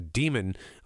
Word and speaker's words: daemon: Essentially, your soul is represented in the form of this animal, which they actually call a demon, although it's spelled D-A-E-M-O-N.